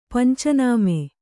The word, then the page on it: ಪಂಚನಾಮೆ: panca nāme